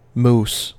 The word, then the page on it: mousse: American English